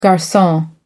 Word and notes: garcon: American English

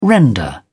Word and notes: render: British English

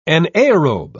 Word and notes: anaerobe: British English

